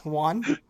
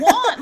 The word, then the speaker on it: Juan: Juan? – That was the worst pronunciation. – No, it's Juan.